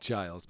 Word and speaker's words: Giles: It is done by Giles Martin. Is that correct? – Giles, but yes. – Yes, Giles. Thank you for the pronunciation.